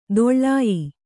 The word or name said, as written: ದೊಳ್ಳಾಯಿ